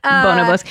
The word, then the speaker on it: Bonobos: Bonobos. You said it right too, because I always am like, Bonobos, it's Bonobos.